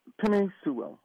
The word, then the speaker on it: Penei Sewell: Penei Sewell, if that's how you pronounce it.